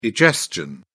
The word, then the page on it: egestion: British English